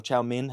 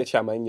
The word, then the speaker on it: chow mein: Chow mein or chow min, how do you say it? – Yeah, chow mein, yeah. – Chow min? – Yeah, some people say... – I've never heard that before. – Some people say chow min.